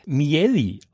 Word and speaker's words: Mieli: Nein, nein, nein, nein, hier, wie ich, wie ich ihn ausspreche, die Millie, Mele, äh, komm, wir fragen nochmal, wie der denn ausgesprochen wird, der, der Neuzugang. – Sein Name spricht sich Mieli aus, weil er familiärerseits aus dem Italienischen kommt.